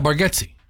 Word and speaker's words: Bargatze: How do you say, is it Nate Bargatze? How do you say his name? – Yeah, Bargatze.